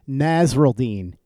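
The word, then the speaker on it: Nasirildeen: Hamza Nasruldeen, that's how you pronounce it.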